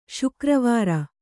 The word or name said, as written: ಶುಕ್ರವಾರ